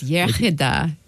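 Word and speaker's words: iechyd da: Yeah, the English say yachida, but it's yachida, good health.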